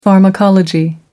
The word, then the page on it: pharmacology: American English